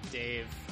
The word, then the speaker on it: Dave: I'm Dave Gonzalez, and because it's the future, I spelled my first name D-A-7-E, but pronounce it Dave.